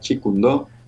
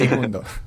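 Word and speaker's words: Jeet Kune Do: En chino cantonés se pronuncia Chi Kung Do.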